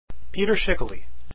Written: schikele

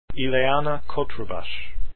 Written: Cotrubas

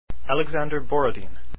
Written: Borodin

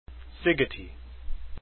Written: Szigeti

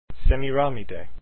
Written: semiramide

